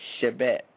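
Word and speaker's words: Shebet (שבט): Now the word for this rod, staff, scepter is Strong's number 7625, and it is Shebet.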